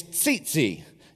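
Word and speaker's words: tzitzit: And the Hebrew word for tassel is tzitzi. It's T-Z-I-T-Z-I-T. If you're from Dylan, that's T-Z-Z-I-T, but that's not how you say it, okay?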